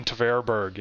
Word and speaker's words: Tverberg: A few questions on Ryan Tevberg, or however you pronounce his name. – Oh, Ryan Tverberg, yes.